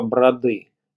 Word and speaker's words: Броды: Старое название Гребенево – Броды. Именно так, с ударением на второй слог.